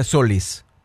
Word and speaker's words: Solís: Así como está escrito, se pronuncia Solís. – Claro. – Pues Solís es S-O-L-I, claro. Tilde S. – Ya, ella dice Solís, debería ser aguda. – Solís. – Debería. – Es una palabra aguda.